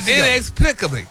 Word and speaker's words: inexplicably: Start. You say NX. – NX. – All right, break it down to a syllable. – Plick. – Yeah. – A blink. – Yes. – Inexplicably. – There you go.